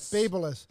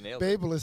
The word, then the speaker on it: Babyliss: So listen, a few minutes ago, I was on the Babelous stage and Babelous.